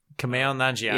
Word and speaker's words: Kumail Nanjiani: Kumail Nanjiani? – Yeah, there we go.